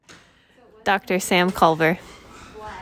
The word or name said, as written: Dr. Sam Culver